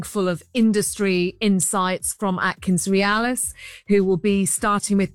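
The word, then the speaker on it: AtkinsRéalis: Have I said that right? Is that right? Atkins Realis? – That's perfect, Richard. Yeah, absolutely perfect.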